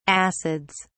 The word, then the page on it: acids: British English